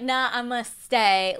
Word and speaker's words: Namaste: Joke of going like, Namaste listening. – Yeah. Yeah. No, but like you can say it. You just did. You just say it in the right pronunciation.